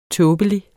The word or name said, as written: tåbelig